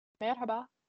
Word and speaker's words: merhaba: Merhaba. – Like, the R's are wrong. – Oh, merhaba. – But it's a me and not ma. – Yeah, it's M-E. Oh. Not M-A. So M-E-R-H-A-B-A.